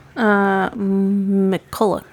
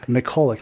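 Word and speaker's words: McCullough: The creator, Christopher Mulchick? How do you say that? – McCulloch. – McCulloch, thank you.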